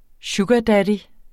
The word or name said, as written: sugardaddy